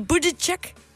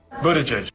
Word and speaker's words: Buttigieg: it all boils down to the vowels. Buttigieg. The vowel in the first syllable is not like boo, but like book. Uh, book. And the last syllable isn't the a vowel like in judge, but it's the unstressed i vowel like in steerage. Idge, idge. So not uh, but uh. Buttigieg, judge, judge, judge, Buttigieg.